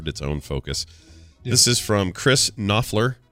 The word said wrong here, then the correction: Kopfler: I'm going to say Koppler because there's no silent N in there. – It's like Knopfler without the N.